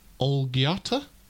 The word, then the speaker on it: Olgiata: They're playing at Olgiata Golf Club. Have I said that right? – Sounds right.